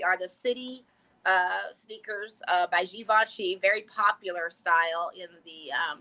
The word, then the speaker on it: Givenchy: I know everybody tries to pronounce Givenchy, but it's Givenchy. If you're gonna pay $650 for sneakers, you need to at least pronounce it right.